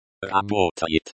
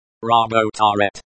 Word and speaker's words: работает: Работает. Работает, emphasis on the second syllable.